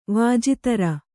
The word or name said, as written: ವಾಜಿತರ